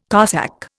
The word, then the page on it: Cossack: Indian